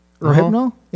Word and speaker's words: Rohypnol: Cough syrup, LSD, rafiponal. Is that how you pronounce that? – No idea. – I screwed it up. – Rohypnol? – Yeah, there you go.